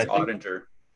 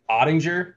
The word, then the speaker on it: Oettinger: Is it Oettinger or Oettinger? – I think it's Oettinger. – Oettinger. All right, there you go. Third time's a charm.